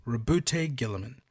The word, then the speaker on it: Roboute Guilliman: The 13th Legion, known as the Ultramarines or the Primarch, I'm going to say it right this one time, Rebute Gilliman. – Mm-hmm. – That's how it's genuinely pronounced.